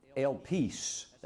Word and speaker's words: elpis: In the Greek, it's the word elpis. That's how you pronounce it.